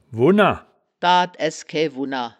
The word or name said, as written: wunner